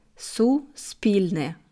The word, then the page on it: Suspilne: slow